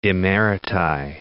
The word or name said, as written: emeriti